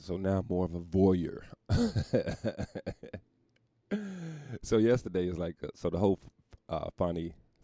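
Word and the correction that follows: Fani: How do you pronounce that shit? I think it's pronounced Fonny.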